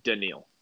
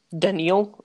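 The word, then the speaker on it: Danielle: Yeah, I did also just want to say it's pronounced Daniil. – Daniil? Oh, okay. Daniil Hunter.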